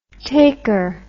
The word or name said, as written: taker